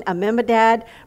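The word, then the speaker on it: Amminadab: Ram begot Mimabadad. I said that wrong, but you know what I mean. Amimadad begot Nashon, and Nashon begot Salmon.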